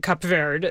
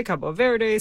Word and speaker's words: Cape Verde: One, we just heard the man himself say Cape Verde, the right. English pronunciation. Yes. You and I are going with Portuguese, which That's right. What people there would say, Cabo Verde, same country.